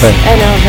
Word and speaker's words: NAV: And is it, I don't know if I pronounce it correctly, but is it NAF that the organization... – NAF? – Is it NAF? – NAV? – Yes, NAV.